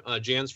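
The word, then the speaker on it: Janz: Well, I'm very active on Twitter, Jans for Congress.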